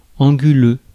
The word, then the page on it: anguleux: /ɑ̃.ɡy.lø/; /ɑ̃.gy.lø/; France